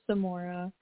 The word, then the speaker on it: Samora: Hi, this is Samora.